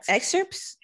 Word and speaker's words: excerpts: I think one of them we were playing around with was the idea of reading like story excerpts. Is that how you pronounce it? – Yeah, excerpts. – Excerpts. I know it's a P. Excerpts.